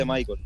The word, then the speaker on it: Michael: No, mi nombre se escribe Michael, pero se lee, cuando se pronuncia, se dice Michael.